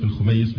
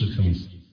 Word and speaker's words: الخميس: فأشهر من ألف وأغزرهم ما مد هو فضيلة الدكتور محمد عثمان أظن الخميس، أعتقد بتنطق الخميس مش الخميس.